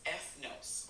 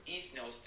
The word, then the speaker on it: ethnos: When we look at the word nation, the Greek word is ethnos, or ethnos, depending on how you'd like to pronounce it, E-T-H-N-O-S. It has a few different meanings, and ethnos or ethnos is where we get our modern American word ethnicity.